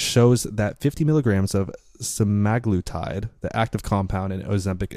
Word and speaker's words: semaglutide: Semaglutide.